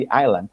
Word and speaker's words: Island: Se no cuesta nada saber que la S es muda y dices Fantasy Island. El problema con eso es que todo el mundo lo oye y entonces, pues todos mis alumnos dicen Island, no se pronuncia con S, porque estar harto de lo, o lo oyes en televisión, está bien.